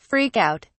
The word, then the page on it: freak out: British English